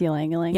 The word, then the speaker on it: Ylang Ylang: Number 3 is the balance oil, and it's got Lang Lang oil. I can never say that. Ylang Ylang. – It's Ylang Ylang. – Ylang Ylang. I can never say that right.